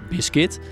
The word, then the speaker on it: Bizcuit: Hessel, H-E-S-S-E-L, Kuik, K-U-I-K, is de CEO en eigenaar van Biscuit. Dit spreek je uit als Biscuit, maar schrijf je als B-I-Z-C-U-I-T.